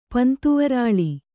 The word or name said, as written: ಪಂತುವರಾಲಿ